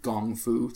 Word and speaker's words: gong fu: which is funny because we spell it G O N G F U, gong fu tea, uh, which is funny because it's the same exact as um, it's the same root as kung fu.